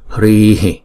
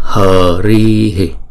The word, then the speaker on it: hri: Chúng ta đừng đọc là hờ ri hì, chúng ta đọc là hri, hri, hri.